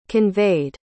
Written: conveyed

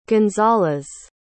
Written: gonzales

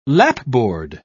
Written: lapboard

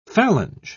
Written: phalange

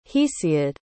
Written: hesiod

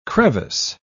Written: crevice

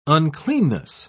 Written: uncleanness

